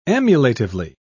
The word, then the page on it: emulatively: British English